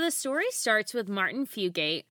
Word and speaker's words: Fugate: I think that's how you say his name. – Yeah, I'm pretty sure you're right that it's Fugate. And we we have a friend of the pod who is definitely a listener whose last name is Fugate. So I want to make sure we pronounce the Fugate to avoid any confusion.